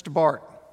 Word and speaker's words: Barth: In 1962, the famous theologian Karl Barth, by the way, if you see his name in print, it's spelled B-A-R-T-H. It's Barth, just so you know.